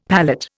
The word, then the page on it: palate: Indian